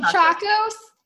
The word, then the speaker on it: Tchacos: It's TCHACOS. – Chacos? Sarah, is it Chacos? The sandal Chacos? – Is this where the sandal came from? – Pretty sure it is. Yeah. Chacos, y'all. And they're so comfy, and they're a sandal, and you can hike in them.